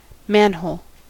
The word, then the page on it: manhole: American English